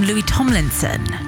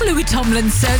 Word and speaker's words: Louis Tomlinson: And then in the 5 seconds, you gave us the name Louis Tomlinson. – Although you pronounce it Lewis. Forgivable. Forgivable. – Forgivable. George, the correct answer was Louis Tomlinson, so that is correct.